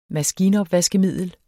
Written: maskinopvaskemiddel

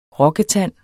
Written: rokketand

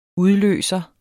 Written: udløser

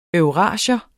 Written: eurasier

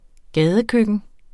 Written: gadekøkken

